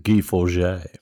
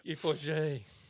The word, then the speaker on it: Guy Forget: Not to forget that Guy Forget is pronounced Guy Forget. – Guy Forget.